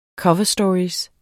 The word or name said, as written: cover